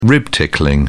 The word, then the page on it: rib tickling: British English